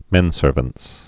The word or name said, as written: menservants